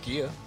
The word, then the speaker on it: GEHA: It's G-E-H-A. I just make fun of it. – Well, no, I mean, that's how it's pronounced. Gia.